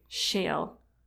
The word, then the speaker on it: shale: Shell or shale? – Shale. – Okay, you said shell. – Shale. I have a really hard time pronouncing that. I am sorry. It's not shell. It's shale.